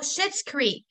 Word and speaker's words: Schitt's Creek: She's from Schmitz Creek. Is that how you say it? – Oh, Schitt's Creek.